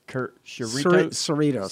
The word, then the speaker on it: Cerritos: Cerritos.